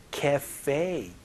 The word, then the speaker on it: cafe: 카페가 아니고 cafe 이렇게 발음하니까 혹은 커피숍이라고 발음해주시면 좋겠네요. 연습 한번 해보죠.